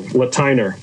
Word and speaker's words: Lateiner: Lateiner.